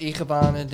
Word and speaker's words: Ikebana: Like you guys Ikebana. Are getting specific in particular. – Is it even pronounced Ikebana, dude? – Ikebana! Yeah, it's pretty close to how it would be pronounced.